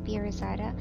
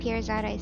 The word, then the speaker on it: Pirzada: It's my full name, Shazia Jamil Pirizada. Shazia is spelled S-H-A-Z-I-A, Jamil's J-A-M-I-L, and Pirizada is P-I-R-Z-A-D-A.